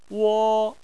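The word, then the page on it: wo: wo1; tone 1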